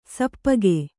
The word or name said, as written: ಸಪ್ಪಗೆ